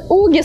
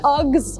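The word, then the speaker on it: UGG: Да это же уги, скажете вы. А вот и нет. Сейчас вы точно так же удивитесь, как и я, когда впервые услышала, как правильно произносить название этого бренда. Акс, Акс, ребята, представляете?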